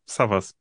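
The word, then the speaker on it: Savvas: Also mein Vater war Grieche und im Türkischen wird es Savas ausgesprochen. Also da wird es aber nur mit einem V geschrieben. Mein Name wird mit Doppel-V geschrieben, von hinten wie von vorne. Savas.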